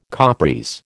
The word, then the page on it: Capris: American English